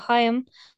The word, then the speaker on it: Haim: This week, we'll be talking about Haim. And no, we're not saying it wrong, and it isn't pronounced Haim. And I just want to clear that up now.